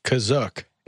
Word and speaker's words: Kashyyyk: Trader as in T-R-A-D-E-R. Like, he has a shop. He sells a bunch of stuff on Kashyyyk. – Which is pronounced Kazook. It is pronounced Kazook in the show.